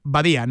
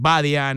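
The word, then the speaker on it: Badia: les digo, eh, no lleva tilde, pero eh, es en catalán. Eh, por lo tanto, es Edgar Badía, no es Badía ni, ni, nada de eso.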